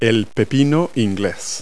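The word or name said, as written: English cucumber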